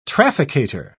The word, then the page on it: trafficator: British English